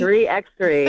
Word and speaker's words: 3x3: Is that how you say it? Not 3-on-3, 3x3? – 3x3, yes.